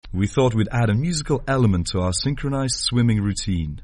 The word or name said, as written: synchronized swimming